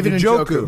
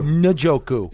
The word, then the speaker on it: Njoku: Help me pronounce it. – David Njoku. – Njoku.